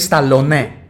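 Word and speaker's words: Stallone: Και εκείνο έτσι το προφέρει. Το, λέ, το λέει Σταλόνε. – Σταλόνε. – Σίγουρο. Το, το όνομά του το προφέρει Σταλόνε.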